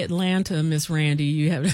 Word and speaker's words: Atlanta: You have to say it right. It's Atlanta. – Yeah, you're right. You're right. And you know, I try to be so like, I try to say it the right way, but people that's from Atlanta be like, no, it's, you don't pronounce that T.